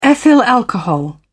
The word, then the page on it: ethyl alcohol: British English